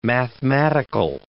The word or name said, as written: mathematical